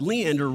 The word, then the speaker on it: Leander: It turns out that Leander rhymes with Lano.